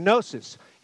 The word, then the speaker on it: gnosis: Some people would pronounce it gnosis because it starts with a G, but gnosis.